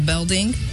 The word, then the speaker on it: Belding: I might have mispronounced the first one. Let me try that again. Principal Belding, Principal Henderson, Principal Miller, Principal Morris.